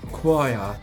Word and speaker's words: choir: Coro era scritto in inglese C-H-O-I-R. Come si pronuncia quella parola? – Quaia. – Capito? Come quaglia, cioè la nostra quaglia.